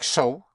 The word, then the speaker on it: so: Because the S in the beginning of a word has a sound of a Z, of a Z. So. So it is not possible. Like so, or that way would be es ist.